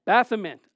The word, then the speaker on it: Baphomet: And he was actually wearing a bell formant. Maybe I didn't say it right. Did I say it right? Baphomet.